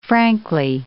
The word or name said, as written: frankly